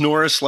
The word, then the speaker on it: Nores: It's Norris like Uncle Chuck, but the other spelling. Yeah, instead of N-O-R-R-I-S, it's N-O-R-E-S, but you had it right on the second run there, Morgan.